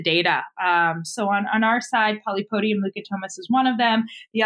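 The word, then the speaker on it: Polypodium leucotomos: if I'm going to say it right, polypodium lucatomus, I think, is that right? – Yeah.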